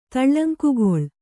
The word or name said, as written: ತಳ್ಳಂಕಗೊಳ್ಳು